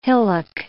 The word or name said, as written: hillock